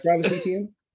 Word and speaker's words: Etienne: I can't say his name right. The running back. – Travis Etienne? – Yes.